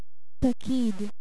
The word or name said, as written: Paqid